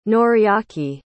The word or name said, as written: Noriaki